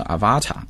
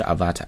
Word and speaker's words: avatar: The avatar called Vivi was designed to be built in on a new virtual reality headset sold by iQIYI, the video platform owned by search giant Baidu. The avatar 这个词很多中国同学会把它读成 avatar, 啊不是 avatar, 重音呢是在第一个音节。